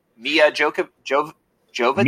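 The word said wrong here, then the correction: Milla Jovovich: Mila Jovovich.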